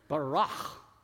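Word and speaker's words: barach (ברא): And if you say it like a Klingon, you're close. Barach.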